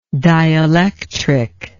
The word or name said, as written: dielectrically